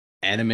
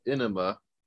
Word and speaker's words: anime: So, not to be confused with enema, which is how my art teacher in college, in animation college, pronounced it. – This town needs an enema. No. – Yep. And I couldn't figure out if she really thought it was pronounced enema or if she was actually trying to slander it.